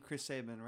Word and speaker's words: Chris Sabin: Chris Sabian? I could be saying his name wrong. But the Motor Machine. – Chris Sabin, right? – Chris Sabin.